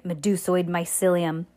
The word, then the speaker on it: Medusoid mycelium: Metasoid mycelium. – No, I forget. – I know. The children thought of... Medusoid. Wow, we're over halfway through the book, and I finally said it right. Medusoid mycelium. Does that sound right? – Yeah.